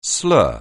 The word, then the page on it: slur: anglais